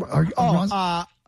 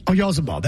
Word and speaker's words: Oyarzabal: Oyarzabal, how you say his name? – Oyarzabal. – Oyarzabal, that's right.